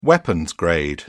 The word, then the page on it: weapons-grade: British English